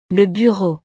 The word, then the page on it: le bureau: frances